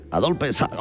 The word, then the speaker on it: Adolphe Sax: Adolphe Sax. – Hombre, así se pronuncia, ¿no? – Vale, digo yo.